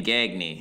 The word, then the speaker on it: Gagne: So, Greg Gagne, no, it was Gagne, right? He pronounced his name Gagne.